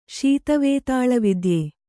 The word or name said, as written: ಶೀತವೇತಾಳವಿದ್ಯೆ